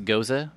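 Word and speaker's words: Gose: The goose. – Okay, that right there is a salted sour. So it's an old German style of beer, pronounced Goza, by the way. – Oh. – Everyone calls it that, by the way.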